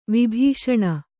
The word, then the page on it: ವಿಭೂಷಣ: vibhūṣaṇa